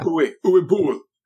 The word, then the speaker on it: Uwe Boll: Uwe Boll, or however you say his name. – Yeah. Uwe Boll.